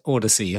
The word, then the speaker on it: Audacy: Yes, it's written Audies, but it's pronounced Audacy apparently, and it's the Mobius 3D headphones, which you might have seen if you subscribe to the Waves email list.